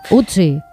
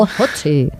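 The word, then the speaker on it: Ötzi: Otzi, creo que se llamaba, un nombre muy, muy noruego. – Utzi. – No sé, lo pronuncias tú mejor que yo. – Utzi, ah, vale.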